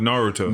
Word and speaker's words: Naruto: Yeah, especially because you don't even know that it's pronounced Naruto.